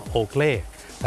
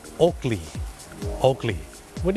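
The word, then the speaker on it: Oakley: คนไทยออกเสียงว่าโอเกลแต่เจ้าของภาษาไม่ได้ออกเสียงว่าโอเกลครับเขาออกเสียงว่าโอเกลวัน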